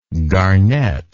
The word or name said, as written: garnetter